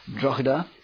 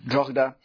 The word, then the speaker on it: Drogheda: I don't know exactly how you pronounce that. It's got the H in there somehow. Drogheda. So that's how I'm going to pronounce it.